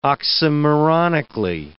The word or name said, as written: oxymoronically